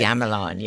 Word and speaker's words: gamelan: And I wouldn't want to end this program without comments from, is it gamelan music? Is that how you say it? – Gamelan, yes.